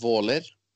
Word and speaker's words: Våler: And but I live actually 15 minutes outside of Moss in a place called Våler. It's spelled with an A with a dot on top. It's kind of hard for Americans to say "oh," but anyway, it's it's a beautiful area.